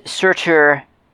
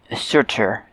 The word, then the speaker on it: Surtr: In Old Norse, it's Surtur, S-U-R-T-R. And so we add a U at the end for clarity for us, for our pronunciation.